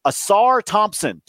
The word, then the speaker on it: Ausar Thompson: Ausar Thompson. Did I say it right? Oh yes, I love him too.